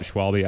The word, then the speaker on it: Schwalbe: Was it Schwalbe? Schwalbe? – Yeah, am I saying that right? – Yeah, I call it Schwalbe.